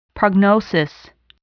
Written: prognosis

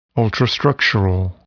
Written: ultrastructural

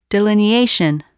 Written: delineation

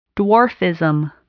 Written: dwarfism

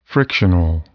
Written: frictional

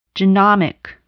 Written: genomic

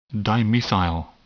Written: dimethyl

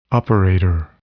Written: operator

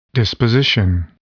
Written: disposition

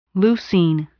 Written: leucine

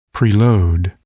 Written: preload

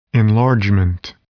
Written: enlargement

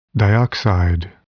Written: dioxide

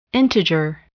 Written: integer

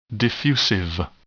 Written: diffusive

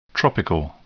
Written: tropical

